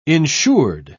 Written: insured

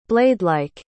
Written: bladelike

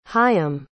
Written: chaim

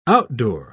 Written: outdoor

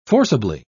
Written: forcibly

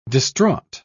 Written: distraught

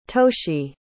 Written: toshi

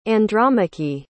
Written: Andromache

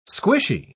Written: squishy

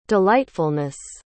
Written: delightfulness

